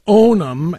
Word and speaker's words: Onum: I mean, I'm seriously, the name Onum, writes Carlton Laster, pronounced Onum as in own them,